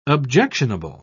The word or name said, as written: objectionable